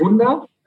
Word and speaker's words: Wunderkind: You emerged as Das Wunderkind. Did I get that okay? – Yeah, it's pretty good. – Okay. – It's more like Wunder.